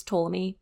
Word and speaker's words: Ptolemy: But it's spelled with a P, so the P is silent. Just FYI, if you've seen this spelled somewhere, it's Ptolemy, P silent.